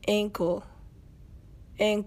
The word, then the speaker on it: ankle: エンコル、ですね。